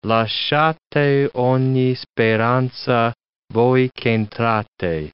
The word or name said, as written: lasciate ogni speranza, voi ch'entrate